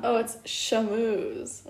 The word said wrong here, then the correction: Charmeuse: Wait, what the heck? Charmeuse. Oh, wait, Charmeuse. But let's listen to the British pronunciation.